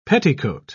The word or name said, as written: petticoat